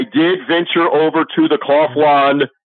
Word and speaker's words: Kaufland: There is a version of Walmart called Kaufland, K-A-U-F-L-A-N-D, pronounced Kaufland.